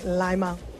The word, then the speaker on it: Lima: It's spelt like lime, so like Lima.